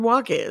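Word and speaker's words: Wacha: Waka is spelled with an H, W-A-C-H-A.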